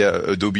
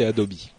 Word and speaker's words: Adobe: Euh, comme tu l'as dit, Adobe, parce qu'on dit Adobe ici. Oui, oui, tu as raison. – Mais même en euh, France, la, prononciation officielle, c'est Adobe.